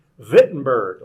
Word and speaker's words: Wittenberg: And when he posted his 95 theses on the wall of the church in Wittenberg, right? – Yeah. – Wittenberg. Yeah, the W in German is pronounced with a V. Wittenberg, like Wagner.